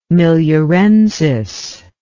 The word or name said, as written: miliarensis